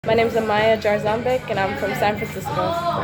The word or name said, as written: Amaya Jarzombek